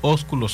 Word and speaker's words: ósculo: Óculo santo. ¿Te molesta un beso? La palabra lo establece. – Sí, pero no le ponga el acento en la U.